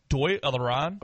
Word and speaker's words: Dáil Éireann: Dáil Éireann.